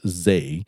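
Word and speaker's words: zei (税): The kanji for tax, pronounced zei, was chosen as the single most representative character symbolic of the social mood in Japan this year.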